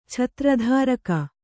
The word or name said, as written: ಛತ್ರಧಾರಕ